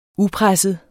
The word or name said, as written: upresset